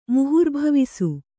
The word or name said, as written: ಮುಹುರ್ಭವಿಸು